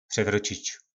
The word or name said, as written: prevrocic